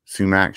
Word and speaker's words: sumac: Do you know how you say it? – Sumac. – That's like the proper Indian way to say it. Like you won't get like looked at wrong.